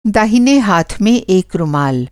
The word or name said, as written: रूमाल